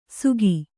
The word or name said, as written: ಸುಗಿ